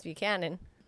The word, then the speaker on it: Buchanan: Because Stephany couldn't even fucking pronounce Buchanan. – Butchanan. – Don't call him that. Sounds like Butchin.